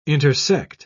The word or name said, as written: intersect